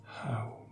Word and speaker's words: Haum: Et pour aujourd'hui, répétez à l'intérieur de vous, dans votre espace intérieur, la graine, le mantra, Haum, H-A-U-M, le H qui se prononce comme une expiration, le U comme un OU, et le M qui s'étend, Haum.